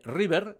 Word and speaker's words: River: River, así como suena, con B y pronunciado en castellano, Rock Band.